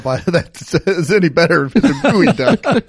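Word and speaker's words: Geoduck: It says here that it's pronounced Gooey Duck.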